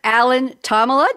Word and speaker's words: Alan Thomalla: We have Dr. Alan Tomala.